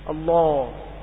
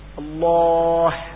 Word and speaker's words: الله: كذلك عندما يقول، وبعض الناس كثير يحدث ذلك في اسم الله، يقول الله ولا ينطق الهاء، فيقول آخرها ألف، ويظن أنه نطقها لكنه لم ينطق لها، الهاء ضاعت لأنه لم يضغط على مخرجه.